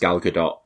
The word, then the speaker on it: Gal Gadot: So yeah, I mean, I think Gal Gadot's, and I'm also aware, I'm sure it's supposed to be pronounced Gal Gadot, but I'm gonna slip in and out of that.